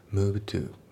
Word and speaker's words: moved: で、あと発音ですけど moved の D が抜けますね。 move to、 moved to じゃなくて move to ですね。